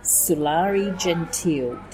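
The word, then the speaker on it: Sulari Gentill: So, we're at the Joogeyong Writers Festival, and I'm welcoming here Sulari Gentil.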